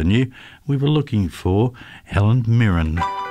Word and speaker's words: Mirren: If I pronounce this right, I think it's Helen Mirren. – It is. Mirren. Well done.